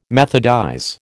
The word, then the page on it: methodize: American English